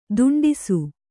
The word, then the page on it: ದಾಂಟಿಸು: dāṇṭisu; Kannada